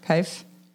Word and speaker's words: Kyf: How do you say that name? K-Y-F. Kif? Brewer? – Kif. Kif.